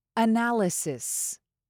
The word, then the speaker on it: analysis: Analysis. Let me just break this up a little bit more slowly first. Analysis. You can emphasize the second syllable, na.